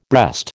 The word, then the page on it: breast: American English